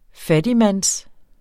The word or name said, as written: fattigmands-